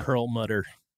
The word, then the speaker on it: Perlmutter: Perlmutter? – Yeah. – Perlmutter.